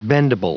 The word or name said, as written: bendable